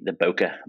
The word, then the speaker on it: bokeh: It's the bokeh. I don't even know how to pronounce it. Does any photographer actually know how to pronounce that? – Bokeh. – Well, I think it's a Japanese word.